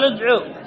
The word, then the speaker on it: ادعوا: طيب كتابتها، كتابتها قال قالوا ادعوا، هذه كتابتها، انظر إلى هنا، قالوا ادعوا، الكتابة موجودة لكن النطق الواو هذه لا تنطق، تحذف لفظا، ذا لفظا. وألف، والألف الفارقة زائدة للتفريق لا علاقة لها بالنطق، ألف الفارقة فارقة. وهمزة الوصل تسقط في درج ايش؟ تسقط في درج الكلام، في درج الكلام. ماذا تنطق؟ اكتب نطقها، قال ادع، قال ادعو، هكذا قال ادعو، قال ادعو، هذا نطقه.